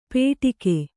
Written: ಪೇಟಿಕೆ

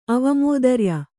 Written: ಅವಮೋದರ್ಯ

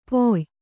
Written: ಪೊಯ್

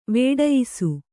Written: ವೇಢಯ್ಸು

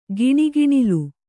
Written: ಘಣಘಣಲ್

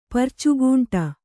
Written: ಪರ್ಚುಗೂಂಟ

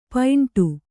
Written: ಪೈಂಟು

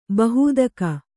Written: ಬಹೂದಕ

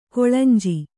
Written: ಕಳೆಂಜಿ

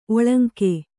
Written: ಒಳಂಕೆ

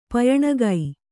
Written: ಪಯಣಗೈ

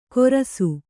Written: ಗೊರಸು

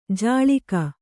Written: ಜಳಿಕ

